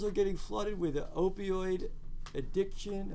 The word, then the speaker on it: opioid: How do you say it? – Opioid. – What? – Opioid. – Am I saying it right? – Yeah. – It sounds weird. Mm-hmm. It sounds like onomatopoeia.